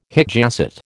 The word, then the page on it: hic jacet: American English